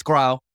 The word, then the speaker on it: Grau: Did I say that right? – No, it's Grau.